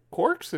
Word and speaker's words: Quarx: And I'm like, Quarks? It was a Q-U-A-R-X. – Yeah. – I think that's how you pronounce that, Quarks, yeah.